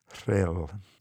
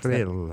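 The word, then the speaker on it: Rhyl: Thrill.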